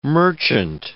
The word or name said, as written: merchant